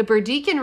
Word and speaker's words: Burdekin: They sent the photos to Queensland Museum turtle expert John Cann, who made his own trip to the Burdekin, am I saying that right? Mm-hmm. The Burdekin River to spot another turtle and confirm that it indeed was new to science.